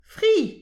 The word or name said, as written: fri